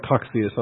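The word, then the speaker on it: Cocceius: Ames was a teacher of Coxius, and I've settled on Coxius on a pronunciation. If you don't like it, then wait till after class and criticize me then.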